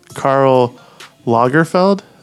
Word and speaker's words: Karl Lagerfeld: Interestingly enough, Karl Lagerfeld, is that how we're going to pronounce that? – Nothing wrong with that.